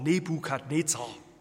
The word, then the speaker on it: Nebukadnezar: Und dieser Opa hieß Nebu, also ausgesprochen Nebukadnezar.